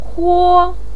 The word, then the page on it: huo: 1